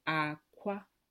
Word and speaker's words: akwa: And we know that clothes in Igbo is akwa. – For clothes. – But then eggs is, yeah, high, low. And then egg is low, high, but they're both spelled the same.